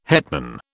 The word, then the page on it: hetman: British English